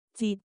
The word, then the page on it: 折: cantonese